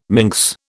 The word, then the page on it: minx: British English